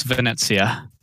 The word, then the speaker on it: Venezia: So the question I want to pose out is, because I'm a huge Venezia player. Did I say that right? Venezia? Where's our linguist? – Close. Venezia. – Yeah, Venezia. Okay.